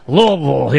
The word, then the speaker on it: Louisville: Louisville. Yeah, you got to kind of slur it around. Louisville. – Just act like you have marbles in your mouth. That's how you say it.